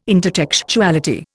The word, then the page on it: intertextuality: Indian